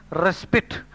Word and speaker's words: respite: Respite or raspite means interval of relief, time for rest. Though the spelling is R-E-S-P-I-T-E, the pronunciation is respite.